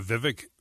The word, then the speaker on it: Vivek: How do you say that name? Vivek? – Yeah, Vivek Ramaswamy.